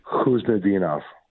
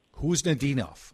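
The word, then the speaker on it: Khusnutdinov: You just don't pronounce the K. It's like Khuznadinov. – Khuznadinov.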